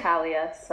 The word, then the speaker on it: Talia: Yeah, oh, it's okay. Talia, so yeah. You got it. – If you would like to introduce yourself. – Yeah, I'm Talia.